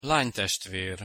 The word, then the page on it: lanytestver: Hungarian